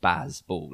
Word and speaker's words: Bazball: It's just rather than having a fancy name, it's two syllables called Baz Ball. – Like that, that's it.